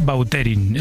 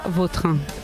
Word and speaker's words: Vautrin: Es cada que uno a mí me suena mejor Bauterín. Es como... – Da más fuerta, ¿no? – Más fuerte, sí, más pronunciación. – Sí. Elegante. La original francesa, Vautrin.